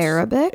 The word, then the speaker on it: Arabic: Rubik, I think it's pronounced Arabic. Arabic.